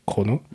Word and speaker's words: Kurono: Alors, ça s'écrit Kurono. Voilà. Mais en fait, prononcé à la japonaise, c'est Chrono.